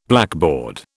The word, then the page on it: blackboard: British English